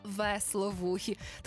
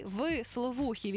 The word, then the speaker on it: висловухі: Це стається кожного разу, коли ми на висловухих котів кажемо весловухі. Тому що правильно говорити, коти висловухі, від слова висіти. У них висять вушка, тому вони і є висловухі.